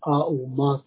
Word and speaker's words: Om: Om, of course, Om, probably you all, most of you already know. Auma, three syllables together, Auma.